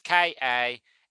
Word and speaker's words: Kanawha: I think that's how you say Kanawa. I don't know. Americans are weird. I'm sure the American who lives in Appalachia would tell me that I'm saying that wrong. It's Kanawha.